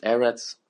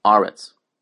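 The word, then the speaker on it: Eretz (ארץ): Sometimes the words will be pronounced Eretz, and sometimes the words will be pronounced Aretz.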